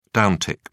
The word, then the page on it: downtick: British English